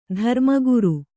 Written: ಧರ್ಮಗುರು

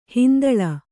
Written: ಹಿಂತಲೆ

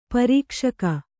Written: ಪ್ರಕಾಶಕ

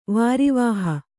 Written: ವಾರಿವಾಹ

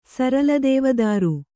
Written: ಸರಲದೇವದಾರು

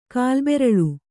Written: ಕಾಲ್ಬೆರಳು

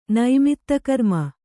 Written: ನೈಮಿತ್ತಕರ್ಮ

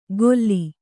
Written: ಗೊಲ್ಲಿ

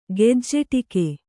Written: ಗೆಜ್ಜೆಟೀಕೆ

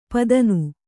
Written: ಪತನ

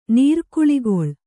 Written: ನಿರ್ಕುಳಿಗೊಳ್

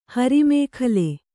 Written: ಹರಿಮೇಖಲೆ

